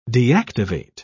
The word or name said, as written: deactivate